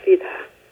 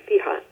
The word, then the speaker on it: fidha: And it's also the source of the word for one word for sorcery, which is fidha. – Say it again. – Fidha. It's F-I-D-H-A. It might be fiha, the way they say it in Irish.